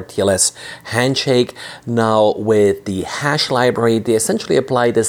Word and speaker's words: HASSH: It's the hash library, I guess is how you pronounce it. H-A-S-S-H, and as the name sort of implies, it fingerprints SSH.